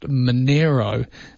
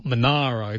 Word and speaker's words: Monaro: And having lived down that area, having lived in the Snow Mountains, I know it's pronounced Monero and not Monaro, too.